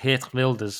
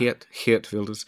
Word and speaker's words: Geert Wilders: Yeah, I read quite an interesting analysis of why Geert Wilders, is that how you pronounce it? – I'm saying, I've heard, because Ed Dutton lived in Finland and he seems very smart. He pronounces it Geert Wilders, so I'm just saying that.